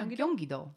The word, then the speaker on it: Gyeonggi-do: Jun-su was born December 15th, 1986 in Gyeonggi-do? – Yeah, Gyeonggi-do.